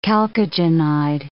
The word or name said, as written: chalcogenide